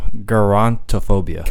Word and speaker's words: gerontophobia: Gerontophobia.